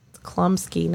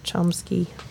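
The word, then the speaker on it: Chlumsky: Klumsky, not Chomsky.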